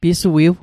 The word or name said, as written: pisuiw